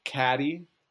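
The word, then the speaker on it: Cady: There's Mean Girls, which is like the one where like it's her name's Katie, right? And her parents like went on all those trips and she like had those fantasies of going wild. – Sean, I believe it's pronounced Caddy.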